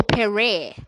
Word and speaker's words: Pere: Perry, P-E-R-E. Let's see how Ebuka pronounces it. Oh, Perry. Okay.